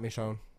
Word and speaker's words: Michonne: Not a fan of the show or the game, so if I pronounced that wrong, I'm sorry. – You got it right, Michonne.